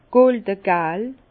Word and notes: goldegal: Bas-Rhin; Herrlisheim

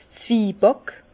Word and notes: 's Zwiebàck: Bas Rhin; Herrlisheim

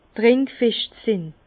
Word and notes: trinkfescht sin: Bas-Rhin; Herrlisheim

